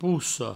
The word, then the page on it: possa: pousso; provençal